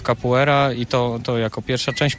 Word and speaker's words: capoeira: Ja myślę, że to może wynikać z pisowni, dlatego, że się pisze capoeira i wiele osób, którzy jakby nie znają do końca wymowy po portugalsku, po prostu czytają tak, jak się pisze, czyli capoeira, a mówi się capoeira.